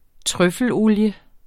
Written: trøffelolie

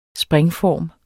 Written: springform

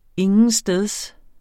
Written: intetsteds

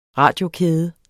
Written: radiokæde

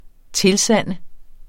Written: TILSANDER

